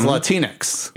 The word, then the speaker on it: Latinx: Yeah, now today's Latinx. – Yeah. – Rhymes with Kleenex.